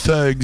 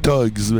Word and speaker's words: Thugs: C'est Thugs ou Thugs? Comment on le prononce? – Thugs, selon les anglais. – C'est ça, on s'est fait dire que ça voulait dire autre chose. – Le H est à prononcer dans le mot. – C'est ça. Mais chez nous, avec l'accent canadien-français qui parle l'anglais, on dit Thugs.